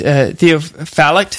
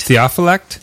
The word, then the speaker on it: Theophylact: How do you say his name? It's Theophylact. – Theophylact.